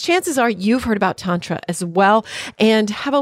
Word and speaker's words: Tantra: I tried to say it right. He says it right. It's Tantra,